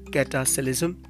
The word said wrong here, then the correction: cataclysm: Cataclysm. It is cataclysm, actually cataclysm.